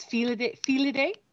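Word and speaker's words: filidh: It's Fila Dé. – Oh, okay. – And it's spelled F-I-L-D-H.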